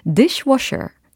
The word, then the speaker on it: dishwasher: dishwasher, dishwasher, dishwasher. 아하, dc가 아니고 dish, dish 이렇게 발음 처리를 해주시고 washer, washer, washer.